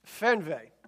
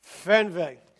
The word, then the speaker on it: Fernweh: The German word for this, according to Google Translator, is pronounced Fernweh. Say it with me. Fernweh. Say it with like German gusto. Fernweh, right?